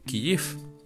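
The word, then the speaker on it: Kijif (Київ): que la pronunciación en ucraniano es realmente Kijif. Y bueno, le escribí y me dijo que es algo así como decir Kijif.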